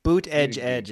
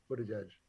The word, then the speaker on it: Buttigieg: I've been saying his name like that, Budding, like the hand, like the thinly sliced Budding? Lunch meats. Budding, like Carl Budding. – Yeah. – Is that not how you say it? – I don't know. Boot edge is what I say.